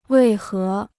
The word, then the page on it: 为何: wèi hé